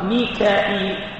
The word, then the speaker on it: ميكال: فمن كان عدوا لله وملائكته ورسله وجبريل وميكال، وميكال تنطق ميكال وتنطق ميكائيل وتنطق ميكائيل، وجبريل جبريل، وجبرائيل وجبرائيل، هكذا كلها صحيح، كلها صحيح.